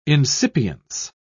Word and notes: incipience: British English

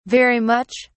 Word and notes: very much: British English

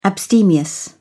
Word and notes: abstemious: British English

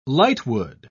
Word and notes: lightwood: British English

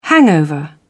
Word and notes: hangover: British English

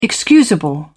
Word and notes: excusable: British English

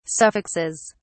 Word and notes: suffixes: British English

